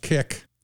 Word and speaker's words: CIC: As part of the CIC, pronounced KIC,